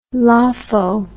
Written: lopho

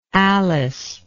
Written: alyce

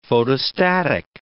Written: photostatic

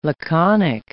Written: laconic

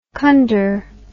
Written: conder

